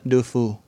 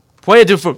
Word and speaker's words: Puy du Fou: The park is Puy du Fou. I'm sure I completely butchered that. – Puy du Fou. – Puy du Fou. There you go. That's a better accent.